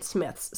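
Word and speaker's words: Psmith: Smith, which also it's humorous because his character says it's P. Smith, but the P is silent, so it's just Smith.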